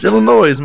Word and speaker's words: Illinois: And the S is silent in Illinois. – It's Illinois, man.